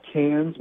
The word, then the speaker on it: Cairns: Well, I will tell you this place, it's pronounced Cairns, but it looks like Cairns.